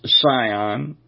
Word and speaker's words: Sion: All right, but it also talks, and you'll see it, one time, I believe it's only once, where it says Sion, but it's spelled S-I-O-N,